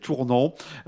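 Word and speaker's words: Tournehem: Donc ça s'écrit Tournoem sur la M, c'est Tournan.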